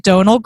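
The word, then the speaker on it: Domhnall: That's how you say it. Donal Gleason.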